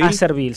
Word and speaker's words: Acerbil: Repetí el nombre, Acervil. Acervil, se escribe A-C-E-R-B I-L.